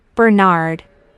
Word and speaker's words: Bernard: A-L-I-S-A. – Thanks. And what's your surname, Alisa? – Bernard. That's B-E-R-N-A-R-D.